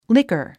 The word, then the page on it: liquor: líkər; リカァ